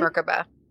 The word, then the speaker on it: Merkaba: There is a Merkaba. Am I saying that correctly? – Merkaba.